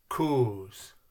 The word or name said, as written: koos